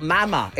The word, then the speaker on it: Wagamama: Dave, it's definitely Mama, M-U-M-M-A. Mama sounds like a baby, says Sally in Manchester. Matt is right, it's spelt Mama.